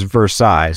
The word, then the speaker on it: Versailles: And he was like, it's not Versailles like you're pronouncing it. It's Versailles. So thankfully, he corrected me before we hit record.